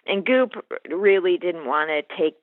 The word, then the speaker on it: Goop: Maybe the one that rhymes with moop recently? – Right. So, yeah, Tina went after Gwyneth Paltrow's Goop company.